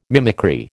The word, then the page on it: mimicry: American English